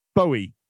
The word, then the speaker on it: Bowie: Bowie.